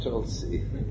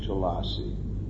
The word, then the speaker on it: Tulsi: No, it's Tulsi. There's no such word as Tulasi. – Dice que no existe la palabra Tulasi, es Tulsi. – Tulsi. Tulasi would have to have the A. A long A, Tulasi.